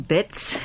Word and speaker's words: Baez: Oh, in Germany it's Joan Betz, and in France it's Joan Baez, and in Spain it was originally Baez.